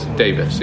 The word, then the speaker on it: Davies: Davis. Yeah, yeah. She pronounced it Davis. Even though it's clearly spelled Davies, but and then yeah, there's some other lighter sessions I'm interested in too.